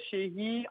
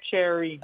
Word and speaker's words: Sherry: Oui, en français, Sherry, en anglais, Cherry.